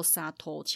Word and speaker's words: 左党: 所以他写作左党，念作沙托，其实和新党一样，都是喜欢喝酒的人的一个代称。